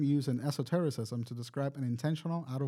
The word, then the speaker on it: Esotericism: So astral projection is or also known as astral traveling, is a term in egoism to describe in how do you say that word? – Esoterism. – Dream terrorism. – Esotericism. – Yeah, that right.